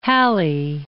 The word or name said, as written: Halley